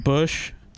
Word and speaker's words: Busch: Can you do a new tab and go B-U-S-C-H? League? – You're right. – Is it Bush? – It is. – It's B-U-S-C-H. Okay. If you're going to use Bush League, spell it right. It's not President Bush. It's Bush as in like Bush beer, right? – B-U-S-C-H.